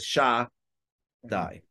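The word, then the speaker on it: Shaddai: I'm going to say Shakai. So there's different ways to say it. You can't say it in the way it's actually pronounced. So you say Shakai or Shedai. The real way is Sha-dai.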